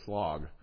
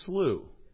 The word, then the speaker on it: slough: So how do you pronounce in the S-L-O-U-G-H of despond? He says slog. Okay. All right. See, this is why I looked it up. It's slew. Okay. It's slew, like through, but slew instead of through.